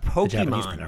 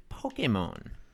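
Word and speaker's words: Pokémon: That is the authentic Pokemon is a Japanese word, and that's how it's pronounced. It's not Pokemon or whatever. It's Pokemon.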